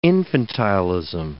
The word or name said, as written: infantilism